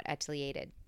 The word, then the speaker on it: etiolated: Because I have unobstructed southern-facing windows in my apartment, and I still have succulents that are somewhat etiolated. Some aren't. Etiolated. That was I learned how to pronounce etiolated on episode 35 of Bloom and Grow Radio with Ryan.